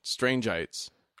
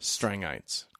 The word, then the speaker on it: Strangites: However, I still want to pronounce it Strangeites. – Oh, are they Strangites? – Strangites. – Are they really? – Yeah, technically.